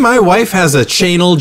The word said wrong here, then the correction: Chanel: jacket. It says it right on the label. – Chanel. You mean Chanel. – Oh, is that how it's pronounced?